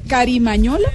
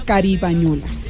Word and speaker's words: Carimañola: también se pronuncia Carimañola o Caribañola. – Exactamente. – ¿Con M o con, B? Con M.